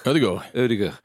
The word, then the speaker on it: Odegaard: Odegaard.